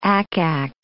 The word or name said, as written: ack-ack